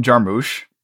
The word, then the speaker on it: Jarmusch: It was written and directed by Jim Jarmusch.